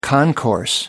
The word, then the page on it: concourse: American English